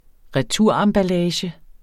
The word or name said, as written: RETUREMBALLAGE